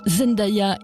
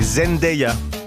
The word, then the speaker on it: Zendaya: Et avouez que sur Insta, Zendaya in Matea Fecal, c'est autre chose que Lily Rose Depp in Chanel, non ? – C'est vrai. Merci Christelle. Par contre, j'ai appris qu'il fallait prononcer Zendaya.